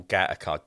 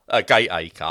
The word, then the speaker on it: Gateacre: And they don't like it when you say Gateacre because it's Gateacre.